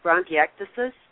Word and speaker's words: bronchiectasis: Like the one word that you didn't pronounce properly, though, was bronchiectasis.